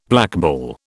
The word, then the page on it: blackball: British English